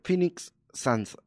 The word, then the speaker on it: Phoenix Suns: Eh, le ganaron a Phoenix, Phoenix Suns, así me dice.